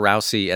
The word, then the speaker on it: Rousay: I recommend it the beautiful record from Claire Rousay, and that's spelled R O U S A Y Rousay.